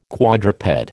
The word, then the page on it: quadruped: American English